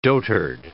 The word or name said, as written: dotard